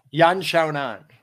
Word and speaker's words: Yan Xiaonan: Yan Xiaonan.